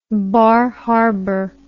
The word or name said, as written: Bar Harbor